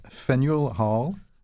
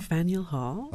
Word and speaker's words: Faneuil Hall: Parmi ses attraits, on note Faneuil Hall, je l'ai mal prononcé encore. – Faneuil Hall.